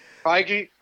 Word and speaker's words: Feige: Feige.